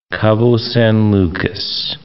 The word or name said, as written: Cabo San Lucas